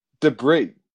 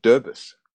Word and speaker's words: debris: No, th- here's another one. Debris. I thought it was Derbis.